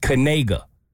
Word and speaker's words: Kanega: because it's actually pronounced Kanega. – Oh, the K ain't silent? – No.